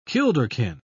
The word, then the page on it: kilderkin: British English